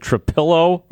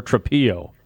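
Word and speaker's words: Trapilo: Is it Trapillo or Trapillo? – I didn't want to correct you, buddy, but it's a pillow. – Okay. – What? – Wait, huh? What? – Trapillo.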